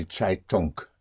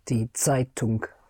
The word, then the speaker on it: Zeitung: Die Zeitung. And the NG in German, unlike in English, the NG is pronounced. Zeitung. The G comes out, the Zeitung. How would you say it? Die? Die Zeitung.